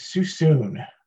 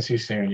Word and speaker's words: Sassoon: It is Sassoon. – Sassoon, kind of like, almost, because the slogan is see you soon type thing, right? – See you soon and Sassoon, yeah. Sassoon. That's the coined phrase.